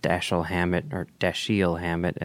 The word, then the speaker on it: Dashiell Hammett: time tested Dashiell Hammett, as we learned, and Raymond Chandler, you know, conventions.